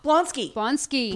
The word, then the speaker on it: Blonsky: There was a name drop a few episodes back where Phil threatened to station Ward in Alaska guarding Blumsky. – Blonsky. Yeah, the Abomination. – Oh, I mispronounced it.